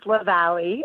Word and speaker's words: Leveille: It's a hard one. It's Leveille. – Leveille. It's supposed to be Leveille. Okay. Right. It's written in French.